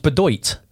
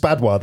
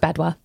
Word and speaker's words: Badoit: It's Badois, though, isn't it? – Yeah, it is. – It's Badois. – Of course, it's not Bedoit.